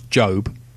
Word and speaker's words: GOB: There's George Oscar Bluth II, which gets shortened to G-O-B, which they pronounce Job, but gets mispronounced as Gob.